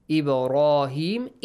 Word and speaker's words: إبراهيم: Fi Ibrahim, Ibrahim, the letter ba has a bouncing sound. Rahim has a heavy sound. He has a long e sound.